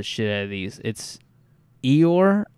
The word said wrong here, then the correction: Igor: Dyatlov. – Igor. – Igor Dyatlov.